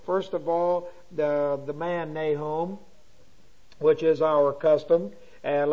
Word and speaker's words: Nahum: So put a dot underneath it, but what I started to give you was N-A-C-H-U-M, Nahum. But we pronounce it Nahum with the soft H, and that's how it comes down.